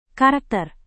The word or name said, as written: karakter